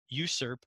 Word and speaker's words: usurp: One master to wield the power and pass on the wisdom, and one apprentice to learn, challenge, and ultimately usurp. I hate that word. I always mispronounce it. The Dark Lord. – You got it right.